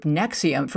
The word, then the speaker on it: NXIVM: This particular group, Nexium, first of all, they are horrible spellers because Nexium is spelled N-X-I-V-M.